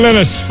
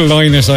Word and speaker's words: Lynott: Phil Lynott, Old Town on East Coast FM. Phil Lynott, actually, to pronounce his surname properly.